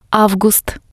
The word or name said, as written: avgust